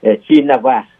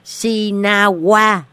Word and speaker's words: Shinawatra: Thì cái tiếng Thái Lan á, cô thấy Shinawatra đó, nhiều người thật họ đọc Shinawatra. – Đọc là chỉ Sinawa, mà Hành Dung đọc thêm cái chữ phía sau nữa. – Đó, nó thấy chữ ra đó, nhưng mà không cần đọc cho đó Sinawa thôi.